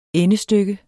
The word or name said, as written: endestykke